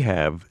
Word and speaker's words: have: La parola have è un'eccezione. La pronunci come se non ci fosse una E finale. – We have 6 cats.